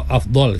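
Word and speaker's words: afdhal: Waktu afdal. Kalau betul dia punya pronunciation-nya lah.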